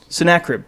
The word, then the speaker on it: Sennacherib: Sennacherib. Sennacherib.